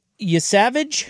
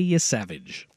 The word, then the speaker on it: Yesavage: Yesavage? Is that how you pronounce it? – I believe you are correct. – The kid. He's only 21. – Yep. Trey Yesavage.